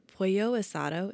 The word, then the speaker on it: pollo asado: Their pollo asado. Is that how you say it? – Yeah. – Pollo asada? Wait, what is it? – Pollo asado.